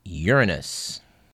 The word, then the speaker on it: Uranus: It was 25 years ago this week that the Voyager 2 spacecraft whipped out to the planet Uranus. And yes, that is the correct pronunciation.